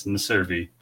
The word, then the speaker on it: Meservy: Yeah, it's Meservy, but it's okay.